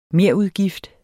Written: merudgift

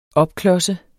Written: opklodse

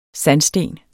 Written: sandsten

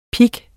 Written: pik

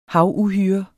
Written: havuhyre

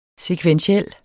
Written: sekventiel

